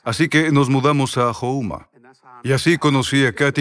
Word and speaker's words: Houma: No Homer, se pronuncia Houma, como los indios Houma.